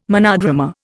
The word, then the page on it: monodrama: Indian